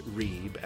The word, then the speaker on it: Reib: And that is spelled B-R-Y-I-N-D-A-L-L. And don't forget, you can search for my name, Edward Reib, and that's R-E-I-B, on the Apple App Store or the Google Play Store, and download my app.